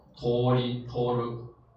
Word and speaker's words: 通る: 训读呢念成什么？念成通り通る啊，通り通る，表示通过的意思啊，通过的意思。